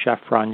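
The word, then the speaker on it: Chephren: It's generally attributed to Khafre, the builder of the second pyramid, or Chephron, you can pronounce it that way.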